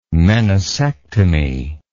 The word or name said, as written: meniscectomy